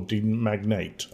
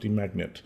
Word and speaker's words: Demagnet: But the thing I wanted to talk about more than anything else was a new game called Demagnet, or Demagnate. I believe it's pronounced Demagnet, which is essentially Portal with magnets, is the best way to describe it.